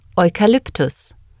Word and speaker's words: Eukalyptus: In German, EU is pronounced similar to OY in the English words boy or toy. Listen and repeat. – Europe. – Europa. – Eucalyptus. – Eukalyptus.